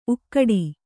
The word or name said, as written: ಊಗಾಡು